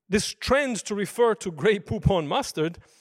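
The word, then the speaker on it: Grey Poupon: And part of the reason could be the fact that just the name of the brand, grey poop-on, lends itself well to rhyming because it rhymes with words such as coupon and futon and neutron and indeed stoop-on.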